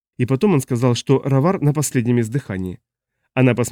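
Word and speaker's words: Ghawar: «Кстати, произносится не так. Сочетание «гх» в арабской транскрипции произносится как гутуральное «р». Он продемонстрировал, закатив звук далеко в гортань. «Не спрашивайте, почему. Система идет от англичан. Как бы то ни было, поле называется «равар».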